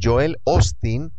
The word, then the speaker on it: Osteen: Es más, hermano, aquí en los Estados Unidos, en la ciudad de Houston, hay un pastor muy famoso americano llamado, se escribe Joel Osteen, se pronuncia Joel Osteen, y es muy famoso aquí en los Estados Unidos.